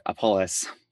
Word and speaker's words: Apolis: Raan Parton from one of my favorite brands out there, Apolis. Did I say that right? You nailed it.